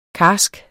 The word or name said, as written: karsk